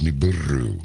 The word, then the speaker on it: Nibiru: Somebody told me the pronunciation was Nibiru. I'm supposed to roll an R on there, so can't really do it.